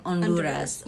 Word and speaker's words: Honduras: And if you're Spanish, the H is silent. – So it's like Honduras. – Honduras. – Fact of the day.